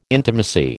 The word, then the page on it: intimacy: American English